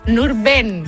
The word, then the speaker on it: Nurben: Yo creo que te falló una vocal. A ver, repítelo. – ¿Nurdin? – Nurben. – Nurben, ¿cómo suena? – Y además es muy curioso porque, no, tal como se escribe, porque no hay ninguna I. Es no, Nurben, no, pero Yesef.